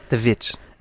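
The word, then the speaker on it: The VVitch: The Witch. – And yeah, it's spelled with two V's. – Witch. Thank you.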